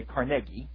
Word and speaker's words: Carnegie: He pronounced it, by the way, Carnegie.